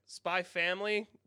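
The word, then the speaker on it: Spy×Family: Eden Zero, Volume 11, Way of the House of Husband, Volume 5, Fullmetal Alchemist, Volume 13, Eden Zero, Volume 12, Spy Family, which is apparently that's the correct way to pronounce the series, is like Hunter Hunter.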